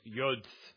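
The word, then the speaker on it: yod (יוד): He says, a jot refers to the smallest character or letter of the Hebrew alphabet, pronounced yod in Hebrew.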